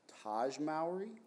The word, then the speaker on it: Taj Mowry: Was it Taj Mowry? – Yes.